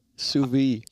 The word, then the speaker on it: sous vide: Is it not sous vide? – Sous vide. – Sous vide. Is it? Yeah.